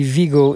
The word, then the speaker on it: Vigo: Well, I guess it would be Vigo in English, but you say the V is pronounced B in Spanish.